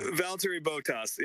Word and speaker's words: Valtteri Bottas: Start with a V. – He kind of pronounces it funny. – Valtteri Bottas. Yeah.